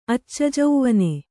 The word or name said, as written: ಅಚ್ಚಜೌವನೆ